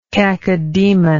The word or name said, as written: cacodemoniac